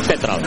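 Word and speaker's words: Tetreault: Y bueno, pues me dio la pronunciación como Tetrault, como si le pusieras acento en la E, porque se escribe Tetrault, con T al final, Tetrault. Pero entonces, pues supuestamente es Jackson Tetrault, la forma en que se pronuncia este apellido de origen francés.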